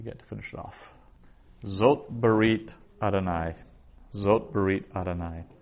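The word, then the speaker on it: zot (זאת): So zot is difficult because that aleph is totally quiescent, so it has no pronunciation in and of itself. It's in terms of how you pronounce it, it's as if it wasn't there.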